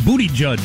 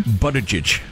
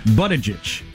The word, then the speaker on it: Buttigieg: So how do you say it again, Peter? – Buttigieg. – Buttigieg. – Yeah. Okay. Or Buttigieg. Close enough.